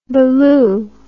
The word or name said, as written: baloo